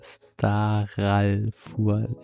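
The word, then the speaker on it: Starálfur: Staralfur. Äh, Star wie Star. A dann mit so einem Akzent.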